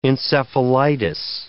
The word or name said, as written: encephalitis